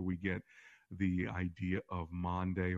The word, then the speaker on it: Maundy: It's spelled M-A-U-N-D-Y, M-A-U-N-D-Y, Maundy, which actually is the Latin word for mandate or command, and this has to do with on Thursday of Passion Week when Jesus had the Last Supper.